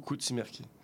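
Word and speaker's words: Kutsi merki: Koutsimerki, K-U-T-S-I, espace M-E-R-K-I. Bon, fait que le U se prononce plus comme un O, Koutsimerki.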